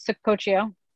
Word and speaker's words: Saccoccio: I'm sorry to pronounce your last name if I butcher it, Sococcio? – Close, Sococcio.